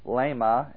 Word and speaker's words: leimma: Now, the word remnant, we have the Greek word here, and it's pronounced lema, L-E-I-M-M-A, and it's a noun from the word lepo, to lack.